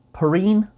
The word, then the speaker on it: Perrine: The 23rd moon to have been discovered ever in 1904 by someone called Charles Perini, P E R R I N E, Perini, Perrine. How would you say that? – Yeah, Perrine. – Perrine. He was born in America, so I don't think it would have been a French pronunciation.